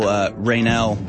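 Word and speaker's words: Reinoehl: All right, folks, we've basically spent this whole first hour talking about this guy, Michael Raynell, apparently is how you pronounce his name, the Antifa murderer who gunned down Aaron J.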